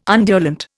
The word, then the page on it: undulant: Indian